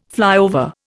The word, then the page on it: flyover: Indian